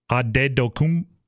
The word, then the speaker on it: Antetokounmpo: By the way, I saw, this is a side note, but I saw him explaining how to say his name. Completely different than everyone's accepted it. It's Adedokupo. There's like five extra letters in there that basically when he moved to Greece, you have to put it in their language.